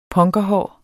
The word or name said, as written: punkerhår